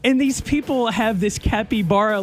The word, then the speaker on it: capybara: And there's a bunch of videos on TikTok of people owning a capybara. – Capybara. – It's spelled C-A-P-Y-B-A-R-A.